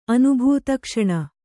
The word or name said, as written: ಅನುಭೂತಕ್ಷಣ